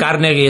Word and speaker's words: Carnegie: El Instituto Aspen es financiado principalmente por fundaciones como la Corporación Carmejie, o como se pronuncia, es, me imagino que es carmesí en otro idioma, o sea, la moradita o algo así. – Es Carnegie, es un banquero de la vieja guardia.